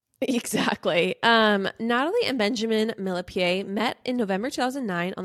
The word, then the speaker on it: Millepied: Apparently it is not Millipede. It's Millepied. Okay. So we broke down the recent cheating scandal on our most recent episode, everyone, I incorrectly pronounced Benjamin's last name as Millepied because that's how it's spelled. – Right. – But apparently it's French and it's actually pronounced Millepied.